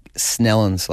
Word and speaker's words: St. Helens: Well, don't say Saint, because I heard you say it before and I was like, man, I know you've heard about, you were talking about people getting their backs up, and I heard it and I was like, what the hell? Who's this talking about? St. Helens like that. – Yeah, I apologize. – So, and you could drop the T and you could just merge the word into one big Snellens like that.